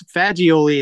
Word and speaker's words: Fagioli: You know, it's Fagioli is the way we say it, but lots of people say Fagioli and every other variable of that.